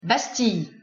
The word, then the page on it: bastille: French